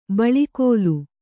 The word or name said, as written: ಬಳಿಕೋಲು